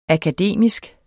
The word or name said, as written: akademisk